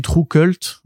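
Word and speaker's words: TRVE KVLT: donc voilà donc c'est qui s'écrit enfin ça se lit True Cult mais c'est en fait c'est T R V E K V L T E oui.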